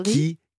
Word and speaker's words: Rikiki: Alors qu'il n'est pas euh, Rikiki. – Qui eh Et oui. L'accent tonique est sur le qui.